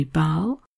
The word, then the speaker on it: Bâle: And thirdly then, another example from Europe, the Swiss city, which has two pronunciations for its name, depending on whether you're thinking in French or in German, and that will be Bâle or Basel, described as follows.